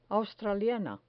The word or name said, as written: australiana